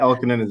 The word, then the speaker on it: Ylkanen: Ylkanen is awesome.